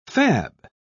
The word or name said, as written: fab